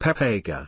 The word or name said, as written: pepega